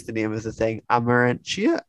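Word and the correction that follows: Amortentia: or is that right? – Amartentia Reads. – Amartentia Reads. – Yeah. Yes. Don't worry. No one knows how to pronounce it, including me.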